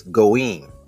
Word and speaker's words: goyim: But the original way you pronounce that term is goyim.